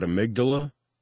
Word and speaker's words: amygdala: So how do you say the word Amygdala? – I'm hoping it's the correct way, but amygdala? – Exactly. As I've got it written out, amygdala.